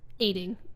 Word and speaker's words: Eighting: Aiding, yes, it's the number 8, I-N-G, and then if you look it up online, it's phonetically Aiding, E-I-G-H-T-I-N-G.